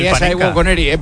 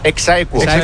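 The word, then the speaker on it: ex aequo: Sería Saegu con Eri, por favor, sería con Eri. – No. Sí. ¿Cómo se dice? – Exaequo. – Yo queres. Dice yo queres.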